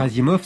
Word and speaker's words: Asimov: D'ailleurs, Asimov, c'était pas son nom d'origine. C'est une manière d'écrire son nom russe, mais traduit littéralement. – Ouais, c'est le O se prononce A, c'est ça ? – Oui.